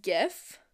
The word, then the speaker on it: GIF: I pronounce it GIF with a hard G.